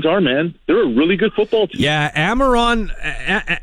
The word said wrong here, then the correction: Amon-Ra St. Brown: uh, uh, sorry, Amon, or Amon-Ra St. Brown is how you say it.